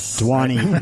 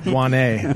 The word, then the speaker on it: Duchesne: Dwani. – We've got Duchesne. – Right. Is that like the Dwani River? – It's Wayne. The D is silent. – Dwane.